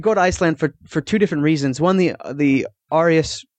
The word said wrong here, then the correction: Aurora Borealis: how do you say that? – Aurora Borealis. – Yes, Aurora Borealis.